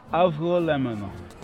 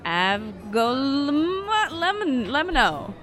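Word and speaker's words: avgolemono: It's not really a hard G sound. It's more swallowed. Avgo lemono.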